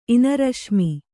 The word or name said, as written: ಇನರಶ್ಮಿ